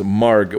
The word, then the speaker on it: Marg: Her first name is Marg with a hard G, Marg.